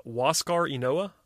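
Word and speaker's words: Huascar Ynoa: It's Waskar Enoa. That's what I'm going with. That's what the pronunciation guide says.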